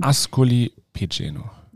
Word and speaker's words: Ascoli Piceno: Ascoli Piceno.